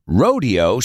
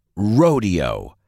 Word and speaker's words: Rodeo: Rodeo se pronuncia rodeo. Sin embargo, la calle de tiendas más famosa de Beverly Hills, Rodeo Drive, se pronuncia rodeo.